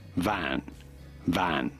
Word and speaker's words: van: Van is not correct, but rather in Dutch they say van, van. Shorter with a more open A sound, van, van.